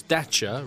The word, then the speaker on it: Dacia: Because it's Dacia, rhymes with Thatcher, all right? Not Dacia, it's Dacia.